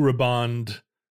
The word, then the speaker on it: Blue Riband: So from the point that the Blue Riband, I need to know if that's the correct pronunciation. It's Riband. Yeah, all right.